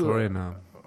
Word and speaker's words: Foreigner: Foreigner.